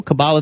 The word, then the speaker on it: Kabbalah: understanding of Kabbalah, with the accent on the last syllable, tends to focus more on, shall we say, the Jewish, that sort of mysticism, as it were.